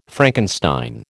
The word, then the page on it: Frankenstein: American English